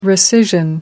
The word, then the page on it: rescission: American English